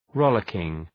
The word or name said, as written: rollicking